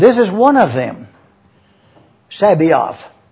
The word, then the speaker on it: Sabaoth: S-A-B-A-O-T-H. I'm saying it slowly and deliberately for you to see it. Sabaoth. Some pronounce it Sabaoth. And they put the S-A and then the rest of the word. Let's stay with Sabaoth. S-A-B-A-O-T-H.